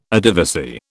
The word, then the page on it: Adivasi: British English